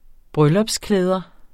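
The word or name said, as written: bryllupsklæder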